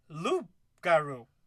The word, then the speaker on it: loup-garou: Loup-garou, with a silent P. It's like loop, like lupin, you know, wolf.